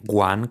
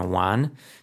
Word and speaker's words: one: Acordaros, no vamos a decir one con G, sino one, two, three, con Z, de zapato, con Z, de Zaragoza, three, ¿ok? Nada de free, con F, ¿ok?